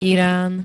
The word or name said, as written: iran